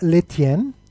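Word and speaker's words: Lestienne: Ja, Dominik ist da, spielt gegen Constant Letien. Das S spricht man nicht aus, bitte, habe ich heute gelernt von unserem lieben Kollegen äh, Alexi Menüsch.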